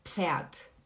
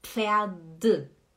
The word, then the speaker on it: Pferd: A gente não fala Pferd, não é D é como se fosse um T, faz som de T, fica pferd, pferd, ó, p, p, p.